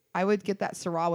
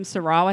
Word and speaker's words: Syrah: I would get that Syrah with that shit. – And that's exactly how you say it. You need to drink some Syrah with that shit.